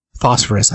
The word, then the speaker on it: Phosphorus: Phosphorus, that's how you say it.